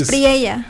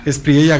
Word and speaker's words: Espriella: Ya pues se dan los resultados, una primera vuelta electoral que creo no muchos esperaban, pero Leonardo de la Espriella, o Espriella, así creo que, Denise, tú dices Espriella. – Espriella, gracias, creo que se pronuncia.